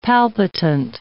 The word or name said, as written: palpitant